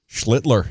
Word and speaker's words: Schlittler: And then the night one is early against, I'm always nervous about, is it Schlittler? – Schlittler. – Yeah, you nailed it.